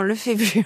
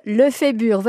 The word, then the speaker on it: Lefébure: Non, Lefébure. Je suis très fière de mon nom de famille en plus. On ne va pas l'écorcher. – Alors, Estelle Lefébure, votre livre s'appelle « Welcome, accueillir le temps qui passe ».